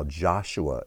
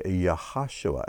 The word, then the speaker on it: Yehoshua: Now, Joshua is how it's pronounced in English most of the time, but there is no J sound in Hebrew. It would be Yehoshua.